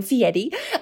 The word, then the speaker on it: Fieri: And so Guy Fieri, I think he pronounces his name Fieri, was on that second season in 2006, where people are competing for their opportunity to have a show on Food Network.